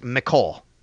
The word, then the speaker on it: Mecole: And by the way, so everybody knows, his name is pronounced Mecole. So everybody knows. I asked him, is it Mecole or Mecole? He said Mecole, because I was calling him Mecole.